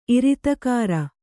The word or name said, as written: ಇರಿತಕಾರ